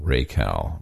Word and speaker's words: Racal: R A C A L, Raycal.